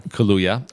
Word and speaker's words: Kaluuya: I liked Daniel Kaluuya. Is that how you say it? – Kaluuya.